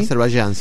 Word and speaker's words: Azerbaiyán: De estas dos ex repúblicas soviéticas, Armenia y Azerbaiyán, ¿no? ¿Se pronuncia así? – Azerbaiyán, sí.